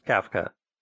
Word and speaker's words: CAFC: C-A-F-C, pronounced Kafka.